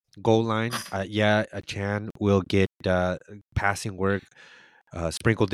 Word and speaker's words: Achane: Yes, you have got to get his name correct. It's Achane. It's not Achane. We were mispronouncing his name, Achane.